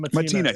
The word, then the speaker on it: Mattina: I'm probably saying that wrong, Chris. I apologize. – Yeah, it's all good. I'm Mattina, yes.